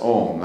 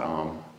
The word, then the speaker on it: Om: Om, pronounced as Om, not Om.